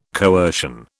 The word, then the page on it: coercion: British English